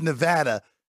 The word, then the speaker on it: Nevada: It's Nevada. And if you've ever been to the DMV here in the state of Nevada, you know within 5 seconds how to say the state's name properly.